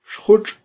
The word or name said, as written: xjutx'